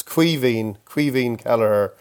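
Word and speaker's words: Caoimhín Kelleher: It's Quivín Kelleher, or you can say Quivín, but Quivín would be...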